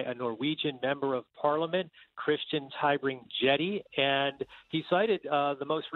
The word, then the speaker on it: Christian Tybring-Gjedde: So listen to, his name is Christian Tybring-Gjedde. I think that's how you say his name. Let's listen. – That is correct.